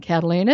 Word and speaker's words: Kataline: Twitter, at Karen Cataline. It's all the same name, just know how to spell it. K-A-T-A-L-I-N-E.